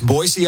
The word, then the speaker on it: Boise: People Everyone, always say it with a Z, right? – That's right. It's Boise, Idaho.